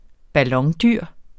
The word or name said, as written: ballondyr